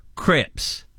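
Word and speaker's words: crêpes: And crepes aren't crepes. They're crips.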